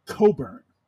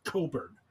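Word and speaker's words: Cockburn: No matter what the spelling of his last name looks like, it's pronounced Coburn. I know, but no, it's pronounced Coburn. Coburn.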